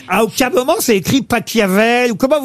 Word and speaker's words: Pachelbel: S'il est allemand, ça se dit Pachelbel.